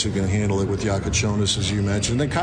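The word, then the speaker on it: Jakučionis: Yeah, I mean, he's taken on a little more of a secondary role with Kasparis, Jakachonis. That's how you say it.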